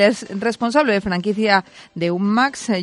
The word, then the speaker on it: UCMAS: Nosotros, por cuestión fonética, pronunciamos UCMAS, que suena un poquito menos seco.